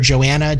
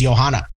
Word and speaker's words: Johanna: I had a student and I kept calling her Joanna, Joanna, Joanna, and only 6 weeks into the semester did I realize she was from Germany and her name was Johanna.